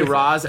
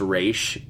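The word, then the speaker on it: Ra's: In the animated series, they say Roz, and then she says... It's actually pronounced Raish.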